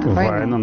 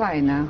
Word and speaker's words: Вайно: Правильно ударение-то я ставлю? Вайно. – Вайно, наверное, финская фамилия, да. – Вайно, хорошо. – На предпоследний.